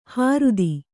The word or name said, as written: ಹ್ರೀತ